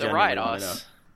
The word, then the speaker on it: Ariados: It's Ariados. Why? This is how you fucking pronounce it.